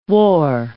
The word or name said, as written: war